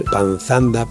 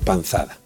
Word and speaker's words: panzada: Así que decir repantingarse es como proferir panzanda por panzada, panzundo por panzudo o despanzurrar por despanzurrar. Recuerde que repantigarse viene de panza, nombre que no tiene más que una N y ya no dudará sobre su pronunciación correcta.